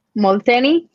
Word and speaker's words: Molteni: Molteni.